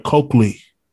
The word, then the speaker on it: Coakley: This is episode number 16, and we have another great guest today, my colleague, Patrice, make sure I say this right, Coakley.